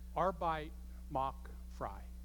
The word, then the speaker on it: Arbeit macht frei: It's pronounced this way: Arbeit macht frei. Arbeit macht frei.